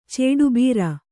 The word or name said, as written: ಚೇಡುಬೀರ